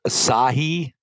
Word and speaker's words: Assegai: Um, it's called the Assegai, and I probably just totally butchered it. Yeah, that sounds right to me.